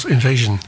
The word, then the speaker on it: INVSN: De omschrijving is INVSN. Uitgesproken als Invasion.